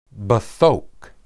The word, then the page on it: bethok: Hebrew